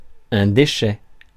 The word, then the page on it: déchets: /de.ʃɛ/